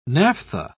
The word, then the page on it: naphtha: British English